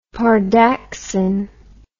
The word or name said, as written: pardaxin